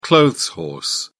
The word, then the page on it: clothes horse: British English